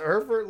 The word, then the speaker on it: Erfurt: So tell me about the Erfurt latrine disaster, is it pronounced? – Yes, the Erfurt latrine disaster.